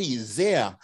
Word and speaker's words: Zier: Today on the podcast, I'm very lucky to have Jerry Zier, rhymes with deer and here.